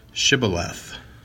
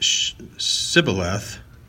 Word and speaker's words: Shibboleth: If he said no, then they would say to him, "Say now, Shibboleth." But he said, Sibboleth, for he could not pronounce it correctly.